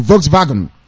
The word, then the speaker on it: Volkswagen: Some of you may be old enough as I am to remember the time that Volkswagen, the correct pronunciation is a German pronunciation, VW, Volkswagen.